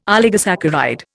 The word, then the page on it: oligosaccharide: Indian